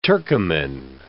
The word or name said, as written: Turcoman